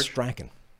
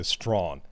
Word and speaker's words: Strachan: Or Strachan. That's how you would say it in the UK. – Strachan? Hmm. So you can get away with that. Like the way it's spelt. as they opposed it, to Strawn. – Strawn. They tell us it's Strawn.